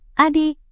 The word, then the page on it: adik: indonesian